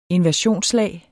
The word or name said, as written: inversionslag